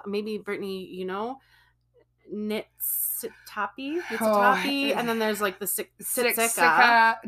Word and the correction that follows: Siksika: No, it's Siksika. It's Tappy.